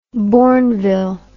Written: bournville

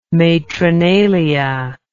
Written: Matronalia

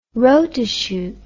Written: rotochute

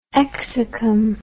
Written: exacum

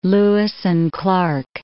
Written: Lewis and Clark Caverns

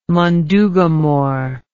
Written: mundugumor

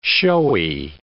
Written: showy

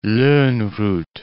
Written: Lonnrot

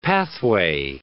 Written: pathway

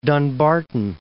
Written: Dunbarton

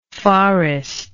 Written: farrest

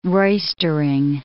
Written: roistering

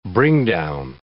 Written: bringdown

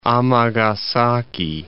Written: Amagasaki

